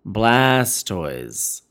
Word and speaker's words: Blastoise: Blastoys. How do you say it? – Yeah, you got it.